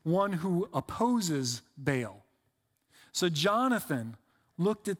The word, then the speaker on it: Baal: It's actually pronounced more like Baal. I just pronounce it Baal because I'm a lazy American.